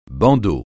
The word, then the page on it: bandeau: francais